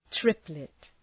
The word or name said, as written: triplet